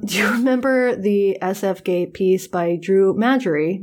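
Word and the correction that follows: Magary: How do you pronounce his name? – McGarry. – McGarry. That was quite the butchering.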